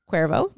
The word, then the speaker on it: Cuervo: So it's a coffee company, a coffee shop called Cuervo, C-U-E-R-V-O, Cuervo.